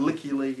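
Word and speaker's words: Lykke Li: This is quite fun, actually, because I looked up the pronunciation of Licky Lee, because a lot of people were done gone say wrong. And Licky Lee is how it is supposed to be said.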